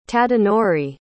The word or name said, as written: tadanori